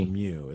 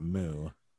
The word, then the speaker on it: mu: But the problem with that is the Greek letters pronounced mu. It's not moo.